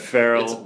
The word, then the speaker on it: Farrell: But Farrell rhymes with sterile.